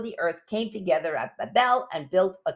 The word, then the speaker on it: Babel: The founder of Babel was a man named Nimrod, or Babel. I think the correct pronunciation is Babel.